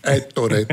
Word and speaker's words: Ettore: Ettore Pagano. – Il y a deux T. Ettore.